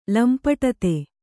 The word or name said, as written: ಲಂಪಟತೆ